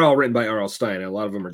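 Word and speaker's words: R.L. Stine: It's part of a film event trilogy on Netflix based on R.L. Stein's Fear Street. That was R.L. Stein. Sorry. Mispronounced his name.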